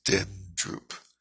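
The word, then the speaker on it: dendrup (བདེན་གྲུབ): It's pronounced dendrup. The B is silent.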